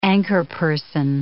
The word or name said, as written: anchorperson